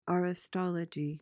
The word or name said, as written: aristology